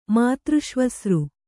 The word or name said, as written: ಮಾತೃಷ್ವಸೃ